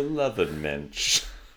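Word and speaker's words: Löwenmensch: The Lovenmensch. – Yeah, that's how you pronounce that. – Isn't it a W? I guess it's German. – Yeah, there's no W's in German, son.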